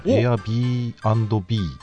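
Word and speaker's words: Airbnb: エア B&B。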